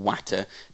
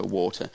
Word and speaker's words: water: She pronounced it yes, but nowadays we would call it yeast, and you mix it with some watter. Now, you still do get very broad dialect speakers in the north of England saying watter for water, but you mix it with water, and then you have to knead it.